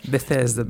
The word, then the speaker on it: Bethesda: Bethesda. – Bethesda, да.